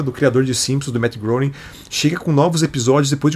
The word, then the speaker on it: Matt Groening: Matt Groening. – Matt Groening.